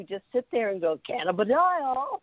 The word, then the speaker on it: cannabidiol: We were sitting, I don't know, outside, and we were trying to figure out, CBD is called, we actually had to Google how you pronounce the official name of CBD. And we got this woman with a British accent somehow pronouncing it for us. And it's called cannabidiol. That's the only way we can say it now, because that's how she said it.